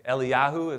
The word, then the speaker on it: Eliyahu: Eliyahu is how you pronounce Elijah, and then Elisha is what we're talking about now.